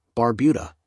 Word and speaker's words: Barbuda: Antigua, pronounced in TGA, and Barbuda, Barbuda, is located in the heart of the Caribbean Sea.